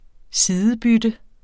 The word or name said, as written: sidebytte